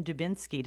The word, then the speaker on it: Dubinsky: But let's talk about Brandon Dubinsky.